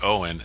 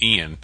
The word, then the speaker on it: Eoghan: Yeah, you know, his first name is E-O-G-H-A-N, and it's pronounced Owen. And some people were pronouncing it Ian.